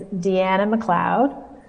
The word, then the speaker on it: Deanna McLeod: So my name is Deanna McLeod, and so you want me to spell it now? – Yes.